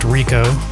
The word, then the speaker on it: R1C0: We're starting things off with something brand new from, I believe it's pronounced Rico, R1C0.